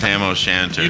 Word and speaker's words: Tam O'Shanter: I pronounced Tam O'Shanter. – You did. You did good.